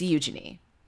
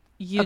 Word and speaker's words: Eugenie: But after listening to Audible and hearing Harold say it about 8,000 times, it's Eugenie. – Eugenie.